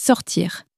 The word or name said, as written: sortir